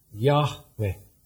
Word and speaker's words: Yahweh: So it was probably pronounced something like Yahweh.